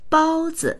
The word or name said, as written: bao1zi